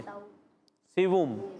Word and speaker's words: سوم: سوم نہیں یہ دیکھیں سیووم کیا ہے سیووم ہوتا ہے نا تیسرا جو تیسرے کو کہتے ہیں سیووم